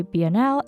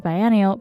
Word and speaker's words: Biennial: In the interview you'll listen to later on, I pronounce it as Taipei Biennale as I mix it up with how the Venice Biennale is pronounced, so my bad, but I think it's clear enough.